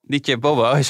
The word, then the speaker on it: DJ Bobo: DJ Bobo, zo spreek je het uit.